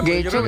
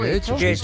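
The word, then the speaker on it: Getxo: En Guecho, creo que nunca sé cómo se pronuncia. – ¿Guecho? – Guecho, yo creo que lo pronunció bien.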